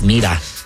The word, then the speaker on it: Mira: Mira. – Look. – I believe Mira. – Look. – No, that's Mira. That's Mira. – But it's spelled like that.